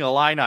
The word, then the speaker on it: Illini: Starting off with the Wyoming Cowboys versus the Illinois Fighting Illini. Am I saying that right? – Because yeah, that was dead on.